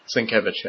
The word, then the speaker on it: Sienkiewicz: I think it actually, I guess it's Sienkiewicz? – Sienkiewicz, yeah. – Okay. Reminded me of Bill Sienkiewicz.